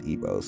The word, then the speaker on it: Igbo: Red Igbo, because sometimes it says Igbo like G, but it's pronounced Igbo.